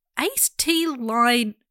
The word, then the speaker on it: acetylene: They were like, I did look up how to say this and now I've forgotten. Acetylene.